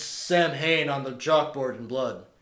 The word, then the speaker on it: Samhain: Yeah, I said it, so it's Samhain. It's spelled Samhain, and I didn't want to be like one of those like New Age pagan assholes, but it is pronounced Samhain.